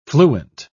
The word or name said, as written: fluent